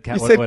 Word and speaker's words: Cavill: Cavill.